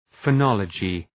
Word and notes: phonology: fə’nɒlədʒı

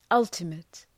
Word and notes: ultimate: ‘ʌltəmıt

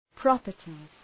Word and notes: properties: ‘prɒpərtız

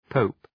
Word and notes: pope: pəʋp

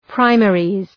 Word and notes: primaries: ‘praımerız

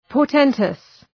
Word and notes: portentous: pɔ:r’tentəs

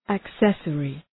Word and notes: accessory: æk’sesərı

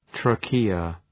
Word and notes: trachea: ‘treıkıə

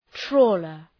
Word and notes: trawler: ‘trɔ:lər

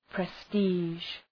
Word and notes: prestige: pre’sti:ʒ

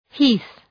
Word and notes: heath: hi:ɵ

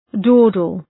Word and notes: dawdle: ‘dɔ:dəl

